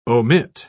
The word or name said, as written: omit